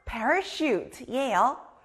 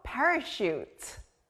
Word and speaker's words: parachute: 또 sh 소리가 나는 다음 단어는 낙하산 parachute예요. sh 소리 들리나요? 사람이 하늘에서 낙하산을 메고 내려오면 아주 안전하게 내려올 수 있어요. 낙하산, 영어로 parachute.